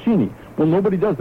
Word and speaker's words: Cheney: But that's not how the family pronounces it. They pronounce it just the same way that the Connecticut Cheneys, as in Cheney Hall, all the Manchester Cheneys. They say Cheney too, and it's not Cheney Hall, it's Cheney Hall.